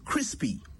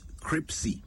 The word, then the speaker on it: crispy: Crispy, not cripsy. Try saying it slowly.